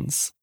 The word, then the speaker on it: Fiennes: How do you pronounce his last name? – Fiennes. – Is it Fiennes? – Fiennes. – Ralph Fiennes. – It's Ralph Fiennes and then Joseph Fiennes.